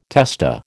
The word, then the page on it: testa: American English